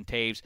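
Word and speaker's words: Toews: And then, that's how you're saying that word. – Taves. – That's how you say it. – Taves. Like T-A-V-E-S.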